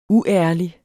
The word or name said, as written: uærlig